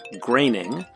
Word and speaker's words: Groening: Question 18, we wanted the correct pronunciation of the creator of The Simpsons' last name, and that is Matt Groening.